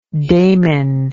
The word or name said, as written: daimen